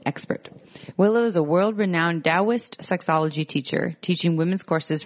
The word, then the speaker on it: Taoist: It's spelled Taoist, but it's pronounced Taoist. – So FYI, if you think like me, Tao.